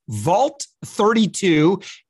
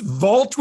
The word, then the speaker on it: Vhault32: You're the founder of Vhault32. Am I pronouncing that correctly? – Yes, actually, great job. Yes, thank you. – It's Vault with an H. It's a silent H. – That's correct.